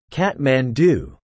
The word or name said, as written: Kathmandu